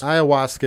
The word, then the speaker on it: ayahuasca: Ayahuasca is a. By the way, that's how you pronounce it. Is ayahuasca.